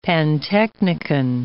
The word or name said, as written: pantechnicon